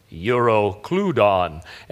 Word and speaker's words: Euroclydon: Now, I would have normally pronounced that Euroclydon phonetically, but I downloaded a new program on my computer. It's a Talking Strong's Concordance. It was free with Bible Explorer, which was also free. And so all week, I just kept pressing, you know, play, and it would go, Euroclydon and stuff. And so I trust the guy that's in my computer telling me how to pronounce that.